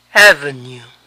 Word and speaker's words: avenue: Avenida, avenue. Avenue. La UE final suena U.